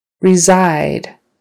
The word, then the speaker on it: reside: Reside. Reside. They reside in California. Reside. The stress is on the second syllable.